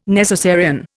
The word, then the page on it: necessarian: Indian